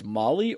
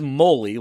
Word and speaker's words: moly: It is Moly, like Holy Moly. So you're right, Tim. I was wrong. I was pronouncing it like the street drug, which I have never done, but apparently have heard of.